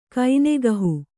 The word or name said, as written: ಕೈನೆಗಹು